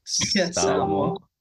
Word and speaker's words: Samoa: So, since you're from, hopefully I pronounce it right, Samoa, You got it.